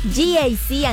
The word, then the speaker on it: GAC: Se escribe GAC, pero se pronuncia GAC. Repítelo. GAC Ángel.